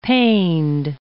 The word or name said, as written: paned